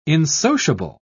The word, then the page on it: insociable: British English